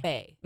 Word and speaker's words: Baie: Bay. – Mm-hmm. There's lots of vowels. – Yeah. Bay's not spelled B-A-Y. – Nope. It's spelled B-A-I-E.